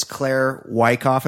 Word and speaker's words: Claire Wyckoff: Our guest joining us now is Claire Wyckoff.